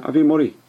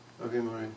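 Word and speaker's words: Avi Mori (אבי מורי): Avi Morati, is that yeah, Avi Mori. – Avi Mori.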